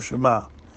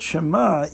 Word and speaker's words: Shema: And it's not clear from the letter what the father Reb Levick wrote to the Rebbe, but something to the effect that the word Shema, even though it has three letters, but in pronunciation, it only takes up the space, the time space of two letters, because the ayin is silent.